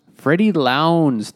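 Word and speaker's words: Freddy Lounds: Freddie Lowndes, which is the way you have to say his name.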